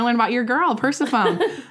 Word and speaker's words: Persephone: Very confidently said her Greek god was Persephone, which, if you know how to pronounce, like we said, Emily's having problems pronouncing words and saying words. It's actually Persephone.